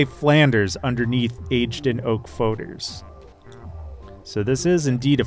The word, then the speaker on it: foeder: Is it Foeders? – Yeah, it's a Foeder. You're exactly right.